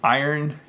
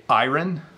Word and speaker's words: iron: Um, but you iron your clothes. Okay. Sometimes people say iron, like they iron their clothes. That is the the probably more formal pronunciation, but I say iron, iron. So I sometimes my shirts are wrinkly, so I iron my shirts.